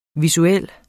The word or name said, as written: visuel